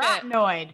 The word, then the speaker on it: carotenoid: Carotenoid. – You got carotenoid.